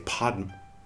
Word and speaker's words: Padma: We should say hum instead of hung, and we should say Pema instead of, or we should say Padma instead of Pema.